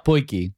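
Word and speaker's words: potjie: I said that right, eh? A poiki. – Yeah, poiki.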